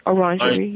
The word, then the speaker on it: Orangerie: but following preceding V, Orangerie, am I pronouncing that correctly? – Orangerie, yes.